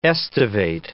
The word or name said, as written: estivate